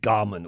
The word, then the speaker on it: German: Apparently, he pronounced his name with a hard G, as in Garman, which is sort of a variant of a Welsh name, Garman, which is a sort of first name.